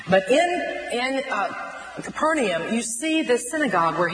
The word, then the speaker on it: Capernaum: Where he was in a fishing village called Capernaum, which it really in Aramaic would have been pronounced Capernaum.